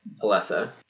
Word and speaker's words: Alessa: I thought it was Alyssa because you saw it written down first, but then they start pronouncing it Alessa, and I'm like, okay, I don't think that's a name, but whatever. – That is a name pulled directly from the first game.